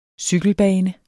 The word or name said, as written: cykelbane